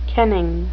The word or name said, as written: kenning